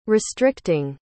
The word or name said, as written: restricting